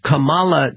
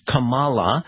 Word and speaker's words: Kamala: which is not Kamala, which is what the Justice Supreme Court said in swearing her in. It's Kamala, okay? Kamala, like comma, and then you put la.